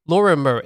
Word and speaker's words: Lorimer: Am I saying this correctly? Lorimer. It's like the train station. – Yeah, you're right.